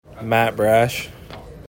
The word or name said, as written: Matt Brash